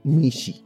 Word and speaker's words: mixi: Ahora el siguiente animal doméstico que estaremos comentando, la traducción al ñañú del mezquital es mishi, mishi. Y se escribe M I X I.